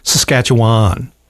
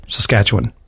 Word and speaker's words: Saskatchewan: Because a lot of people say Saskatchewan. They overpronounce that last syllable. – Why? What? How is it supposed to be said? – Saskatchewan. – Oh, is it? – Yeah. – I say Wan.